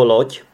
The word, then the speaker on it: loď: Ale pak ještě existuje slovo loď. Loď, což se píše z dě, ale čte se to jako tě.